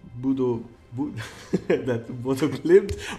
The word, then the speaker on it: Bodø/Glimt: Bodø/Glimt.